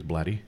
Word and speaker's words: Blatty: I think his name is Blatty. – Yeah, you're right.